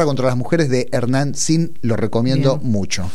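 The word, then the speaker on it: Zin: Pero aprovecho, ya que hablamos del tema, para recomendar el documental de Hernán Thin, sin con Z, por eso lo pronuncio así, que se llama La guerra contra las mujeres.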